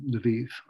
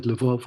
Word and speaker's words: Lviv: in Lviv, or you said Lvov, which is the Russian spelling and pronunciation, and other places in that part of the country.